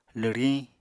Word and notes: lwriñ: apatani

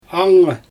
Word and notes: angngy: Xyzyl

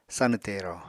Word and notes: sanw tero: apatani

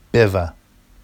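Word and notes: بـِوَ: beva; Vafsi

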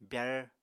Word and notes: byar: apatani

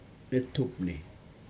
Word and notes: netupni: Aneityum

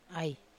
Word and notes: ai: Wamesa

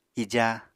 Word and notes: ija: apatani

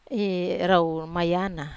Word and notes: mayana rau: Wamesa

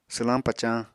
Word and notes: swlañ pacañ: apatani

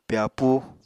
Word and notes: pyapúu: Apatani

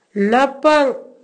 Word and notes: Napang: Naka

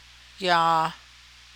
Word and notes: yaa: Navajo

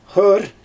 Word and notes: xɯɯr: Xyzyl

